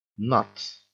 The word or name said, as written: nuts